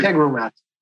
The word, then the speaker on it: Integromat: Integromat.